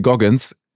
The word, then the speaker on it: Goggins: So, das ist mit, ich muss den Namen nochmal aussprechen, ich glaube, ich habe ihn nicht richtig ausgesprochen für Leute, die ihn googeln wollen. David, David äh, Goggins ganz easy. G-O-G-G-I-N-S, kann ich jedem empfehlen.